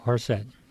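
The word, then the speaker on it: charoset: how do you say it? Horset? – Horset.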